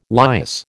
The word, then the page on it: lias: American English